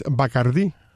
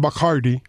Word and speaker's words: Bacardí: A propósito, mucha gente en Cuba no sabe que uno de los patrocinadores principales del canal de pelota es Bacardí. Uh-huh. Aunque aquí pecan y le dicen Bacardi, porque por supuesto todo tiene que ser aquí así. – Aunque la pone acento en la I muy claro.